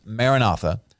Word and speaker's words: Maranatha: That's kind of our English way of saying it, to officially pronounce it in the Aramaic. It kind of phonetically sounds a little bit different, but that's what most people know it as, Maranatha.